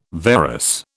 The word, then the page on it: varus: British English